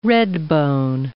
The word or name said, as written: redbone